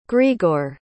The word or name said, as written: grigor